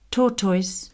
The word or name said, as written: tortoise